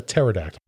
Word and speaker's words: Pterodactyl: Petrodactyl. – Good job. No, Pterodactyl. Yeah, the P is silent.